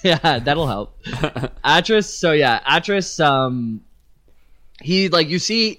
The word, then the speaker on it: Atrus: Artus or Atrus? – It was Atrus.